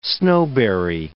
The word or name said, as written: snowberry